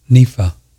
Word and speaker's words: Nenfa: Nepha. Nepha. – Spell that. – N-E-N-F-A, Nepha.